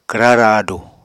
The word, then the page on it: kwra raadu: Apatani